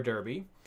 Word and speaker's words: derby: And he lists El Trafico, the Cali Clasico, the Hudson River Derby. I do say derby, not darby, because I'm from Kentucky and I say it correctly.